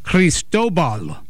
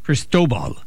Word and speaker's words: Cristobal: Cristobal. – Okay, y'all got that? Cristobal. Not crystal ball, but Cristobal. Just emphasis on the different syllable than I thought.